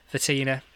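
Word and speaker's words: Vitinha: So it might be quite a stronger team than we think, but you'd think he's going to Silva, how do you pronounce it? Vitina? Vidata? – Vitina.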